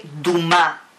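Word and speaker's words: Duma (דומה): Cuando nosotros vemos la palabra impureza, que lo vimos dentro de esta porción, que se dice Duma, se escribe, ahí está, se escribe Dalet Vav Mem Hei.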